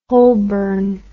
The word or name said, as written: Holborn